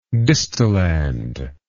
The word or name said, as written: distilland